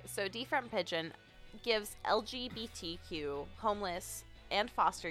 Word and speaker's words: DFRNTPIGEON: This one is called D Front Pigeon, but it's spelled differently. So it's DFRNTPIGEON. So there's no O in the front.